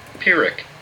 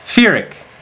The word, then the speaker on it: Pyrrhic: Pyrrhic. – Pyrrhic! Ha ha! Not an F sound, it's a P sound. Pyrrhic.